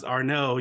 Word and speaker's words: Arnaud: I love French, Steve, because when I looked at it, it kept saying Arnaud, and I'm like, it's pronounced Arno, you sneaky little French people.